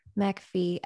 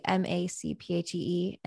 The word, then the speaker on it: MacPhee: And then MacPhee, MacPhee, and then .com.